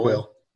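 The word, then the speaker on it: Kuiil: I don't know how you pronounce that. Cole? – Quill.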